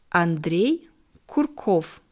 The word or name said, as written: Andrej Kurkow